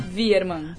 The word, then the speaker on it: Wiermann: Virman.